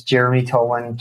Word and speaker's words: Jeremy Tolan: My name is Jeremy Tolan, JEREMYTOLAN.